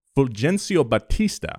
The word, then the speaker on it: Fulgencio Batista: In the mid-50s, he convinced the president of Cuba, Fulgencio Batista.